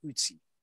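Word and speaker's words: Ötzi: I believe the correct pronunciation is Ootsie.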